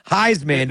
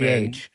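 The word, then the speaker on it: Highsman: Heisman, the but high is spelled H-I-G-H. Heisman.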